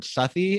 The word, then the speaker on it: Southey: The first one is Wat Tyler, which is by, I'm not sure if I'm pronouncing the name correctly, but Robert Southey, is that correct? – Yeah.